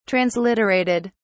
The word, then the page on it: transliterated: British English